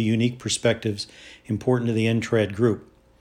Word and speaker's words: NTRAD: The issues faced by the NTRAD group, N-T-R-A-D, pronounced NTRAD, of non-traditional owners are unique to these special venues.